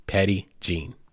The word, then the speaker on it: Petit Jean: Like, any of you out there, well, if you saw the episode title, you saw P-E-T-I-T-J-E-A-N. And you're probably thinking, yo, that's French, bro. That's not Jean, and it's not Petty. I get it. It sounds like the colloquial way of pronouncing it here is P-E-T-T-Y-J-E-N-E, Petty Jean. That's how people pronounce it. But yes, you're correct. It is French, and it is more properly pronounced as Petit Jean.